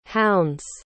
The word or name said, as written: hounds